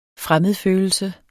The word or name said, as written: fremmedfølelse